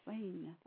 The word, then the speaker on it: Wain: That's called the Veng River. – How do you spell that, please? – I think we can say W-A-I-N. – Wayne.